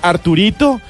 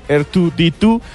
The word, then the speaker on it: R2-D2: ¿Ustedes sabían que ese robot pequeñito que hace unos ruidos, que parece una caneca de esas contemporáneas, no se llama Arturito? Se llama es R2-D2, sino que en la pronunciación en inglés se dice R2-D2, y quedó Arturito para la mayoría de los colombianos.